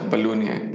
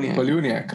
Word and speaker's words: Palahniuk: Chuck Palahniuk. – Palahniuk, right? – Yeah.